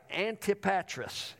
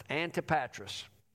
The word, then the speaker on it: Antipatris: And I looked it up, so I am pronouncing it right. Antipatris is how it's pronounced. Antipatris. I couldn't have come out with that on my own.